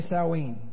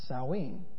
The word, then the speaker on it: Samhain: Say Samhain. It is nothing like it's spelled. Samhain.